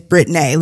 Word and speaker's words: Brittnae: So what happened was, we thought of some names, and for me, my real name is Brittney. Let me spell it for you. That is B-R-I-T-T-N-A-E. It is spelled the way it's said.